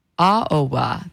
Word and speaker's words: Aoba: And you heard music from, let me make sure I say this right, Ichiko Aboa. Now, if I said it incorrectly, oh, I think I said it right, Aoba. There we go.